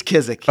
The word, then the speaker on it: Kizik: Now, is it Kizik or Kizik? Because I don't want to put the wrong emphasis on the wrong syllable. – Emphasis. – It's Kizik. – Okay. – You were right up front.